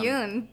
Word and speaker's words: Jung: Isn't that Carl Jung? – That is Carl Jung, whatever. – Yes. – Jung.